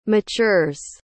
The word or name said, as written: matures